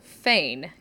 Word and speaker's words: foehn: There's also another word for downslope winds or katabatic winds, which is called foehn. It's not spelled that way. It's spelled F-O-E-H-N, because these also happen frequently in Europe, where you have really large mountains.